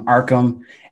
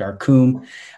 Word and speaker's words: Arkham: I have heard so many different ways of pronouncing this name. It's been botched in its spelling so many times. I've heard Arkum. I've heard RK Ham, like a deli shop. Wreck-em, like Wreck-It Ralph. Like, I've heard everything. So I'll set the record straight. It's Arkham. R-K-H-A-M.